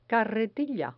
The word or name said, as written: carretilla